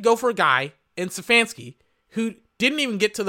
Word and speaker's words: Stefanski: Oh my God, and they fucking get the name wrong as they announce it. It's Stefanski, Kevin Stefanski of the Cleveland Browns, over Campbell and D'Amico Ryans, over Kyle Shanahan.